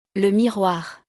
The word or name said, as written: Le miroir